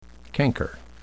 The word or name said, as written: canker